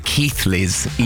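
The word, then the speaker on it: Keighley: They get very protective over the Keithleys, even though it's spelt Keely.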